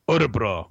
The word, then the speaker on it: Örebro: It always made me laugh whenever he would talk about Örebro, and he would always say it very like with the Swedish, like Örebro. Like he would just say it like really with the correct pronunciation.